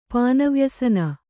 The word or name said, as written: ಪಾನವ್ಯಸನ